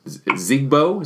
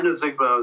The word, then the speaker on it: Ozigbo: So Ozigbo is how you pronounce it, right? – Yeah, I think it's a Divine Ozigbo is how I've been pronouncing it.